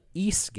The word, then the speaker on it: Ys: pronounced East, like geese without the G.